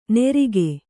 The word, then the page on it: ನೆರಿಕೆ: nerike